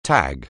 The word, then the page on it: tag: anglais